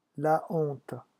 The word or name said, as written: la honte